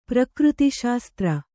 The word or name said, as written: ಪ್ರಕೃತಿಶಾಸ್ತ್ರ